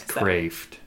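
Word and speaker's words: Kreeft: Yes, Kraft.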